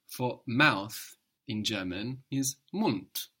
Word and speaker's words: Mund: But the D being on the end of the syllable is being pronounced hard. Mund, mouth.